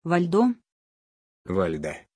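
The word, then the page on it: valda: Russian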